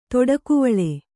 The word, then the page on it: ತಡಿಕೆವಲೆ: taḍikevale